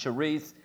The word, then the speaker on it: Cherith: We often pronounce it Cherith, but it's literally pronounced Cherith.